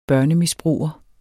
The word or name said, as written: børnemisbruger